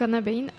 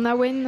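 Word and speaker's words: abhainn: donc Anna Bain. Ça se prononce pas comme ça normalement, mais c'était plus simple comme ça. Ça se prononce en Awen, en Aven, ça dépend le coin d'Irlande.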